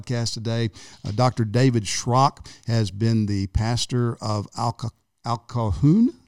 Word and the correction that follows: Occoquan: Did I say it right? – Occoquan Bible Church.